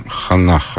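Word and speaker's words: Ханах (חנה): И даже само имя Анна, которое так воспевал Гумилев, конечно, это же библейское магическое имя. Ханах оно читается.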